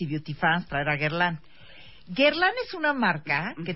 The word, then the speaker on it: Guerlain: ¿Se dice bien? ¿Lo dije bien? Sí, es correcto. Guerlain.